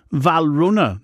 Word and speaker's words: Valrhona: What's Valhorny? – Trifle. – Hold on a minute. – I've mispronounced that. Valrhona, not Valhorny.